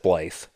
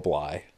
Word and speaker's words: Blythe: So for the longest time, I said Blythe. But apparently it's Bly, according to him.